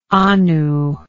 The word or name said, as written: anu